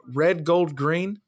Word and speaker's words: RDGLDGRN: And they go by the name Red Gold Green, but they spell it They spell it RDGLDGRN. So it's kind of like they shorten each one.